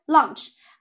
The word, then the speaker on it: lunch: 嗯，要注意一下啊，这也是这个商务英语当中的一个非常重要的词汇啊，launch，OK，啊，它是不是发音跟这个午餐 lunch 啊非常像，对不对？那它其实不太一样，lunch 是没有 a 的，所以它是 on 的音啊，on 的音啊，launch，那这个是 launch，launch，OK，它有个 o 的音，因为它是 a u n，OK，o 啊，launch，OK，got it 啊？